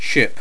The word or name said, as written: ship